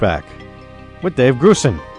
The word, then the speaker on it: Grusin: All right, we'll be right back with the Dave Grusin, and it is Grusin, folks, I've been mispronouncing it for 4 years, Grusin.